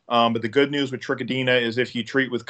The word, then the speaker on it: Trichodina: And, the last one, I'm going to butcher this name, Trichodinia. Is that correct? – Yeah. Trichodina.